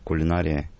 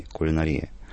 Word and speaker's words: кулинария: Еще в середине 20 века была конкуренция между ударениями кулинария и кулинария. Сегодня, видимо, все-таки кулинария не говорит практически никто, но, может быть, только люди старших поколений, совсем уж старших.